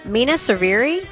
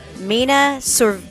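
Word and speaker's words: Mena Suvari: Mina Saviri, did I say that right? – No, that's about as wrong as it gets. – Help me. – Try it again. – Mina Saviri. – That's it.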